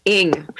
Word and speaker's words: Ng: It's called Ing.